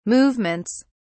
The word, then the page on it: movements: British English